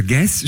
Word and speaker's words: Guez: G-U-E-Z, genau. Also Guess spricht man das aus, das ist ein Franzose, kann aber sehr gut Deutsch, hat lange in Deutschland studiert.